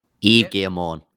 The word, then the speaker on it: Yves Guillemot: Ubisoft's CEO, how do you say his name? Ves Guillermo? – Yves Guillemot. It's French.